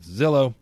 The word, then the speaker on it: Zillow: No, it's supposed to rhyme with pillow, and therefore we call it Zillow.